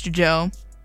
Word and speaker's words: Djo: Yes, I know it is spelled DJO. It is pronounced Joe.